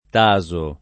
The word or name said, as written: Taso